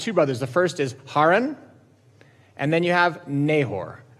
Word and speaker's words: Haran: Found out it's properly pronounced Haran, which is very hard for me to say, but I'm going to try.